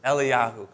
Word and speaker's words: Eliyahu: All right, but it's actually pronounced Eliyahu because they don't pronounce the J. So Elijah is not Elijah. It's actually Eliyahu.